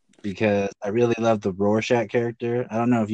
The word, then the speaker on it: Rorschach: Oh yeah, Rorschach. – Rorschach.